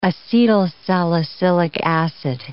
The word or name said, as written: acetylsalicylic acid